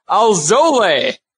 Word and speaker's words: Alzolay: I'm right now seeing Alzolay. So Alzolay is what we're going to be going with here.